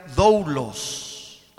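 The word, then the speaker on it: doulos (δοῦλος): Esta palabra, mis hermanos, servir aquí, viene de la palabra griega que se pronuncia doulos.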